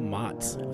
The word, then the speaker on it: Matz: This is the Young Musicians Guide podcast, and today we had Rachel Motz said it right this time.